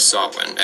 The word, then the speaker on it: Samhain: It's spelled S-A-M-H-A-I-N, but because Irish is a confusing language, it's pronounced Samhain.